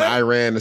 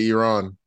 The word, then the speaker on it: Iran: Yeah, he was saying Iran instead of Iran.